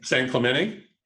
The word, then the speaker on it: San Clemente: San Diego yeah, and then we went to San Clemente. I don't know if I'm saying that right. – San Clemente. – Yeah, yep.